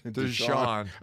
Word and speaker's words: Deshaun: And Deshaun Watson, some of those throws he made, that one jumped, Deshaun. – I got the wrong emphasis on the wrong syllable.